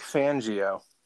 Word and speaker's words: Fangio: Their coach – Vic Fangio. I keep wanting to pronounce it like vagina, like it rhymes with vagina for Fangio.